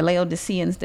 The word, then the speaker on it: Laodiceans: The seventh church, the Laodiceans, did I say that right? – Yep.